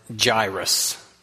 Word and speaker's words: Jairus: How you pronounce J-A-I-R-U-S, I have always pronounced it Jairus. And then as I heard Scott read and he pronounced it differently than me, I happened to have my phone sitting there because my watch band broke, so I needed it for time. I quickly Googled, how do you pronounce this name? And apparently the correct pronunciation is Jairus.